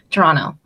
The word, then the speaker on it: Toronto: I have a friend from Toronto, and apparently you pronounce it as Toronto. You don't pronounce the T.